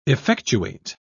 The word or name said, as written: effectuate